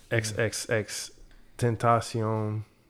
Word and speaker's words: XXXTentacion: Don't Cry with XXXTentacion.